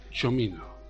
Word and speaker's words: Cimino: Carlos, esta mañana he estado viendo las pronunciaciones y se dice Michael Cimino. – Bueno, se escribe Michael Cimino, pero Cimino no dice Cimino.